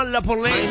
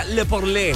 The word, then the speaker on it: Le Porlé: Que se juntó con The King DJ para hacer esto que se llama La Porlé. No, se pronuncia Le Porlé. Así como francés.